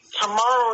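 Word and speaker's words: Tomohiro: How do you pronounce his name here? Tomario? I say Tomario. – Tomaro Ishii. He's from New Japan Pro Wrestling.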